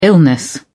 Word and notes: illness: British English

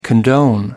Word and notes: condone: American English

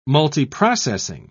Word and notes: multiprocessing: British English

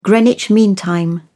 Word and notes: greenwich mean time: British English

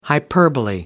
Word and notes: hyperbole: American English

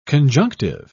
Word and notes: conjunctive: British English

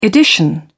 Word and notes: edition: British English

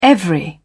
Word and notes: every: British English

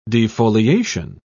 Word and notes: defoliation: British English

